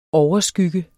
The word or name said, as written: overskygge